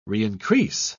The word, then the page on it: reincrease: British English